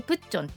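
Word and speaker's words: プ ッ チ ョ ン (북촌): いくつか印象深かったところご紹介したいと思います今回行って一番楽しかった良かったなと思うのがあの日本語で言うと北村南北の北に市町村の村で北村でこれでプッチョンって読むんですけどプッチョンハノクマウルと言いまして関谷村ですね昔ながらの家屋がたくさん残ってるんですね。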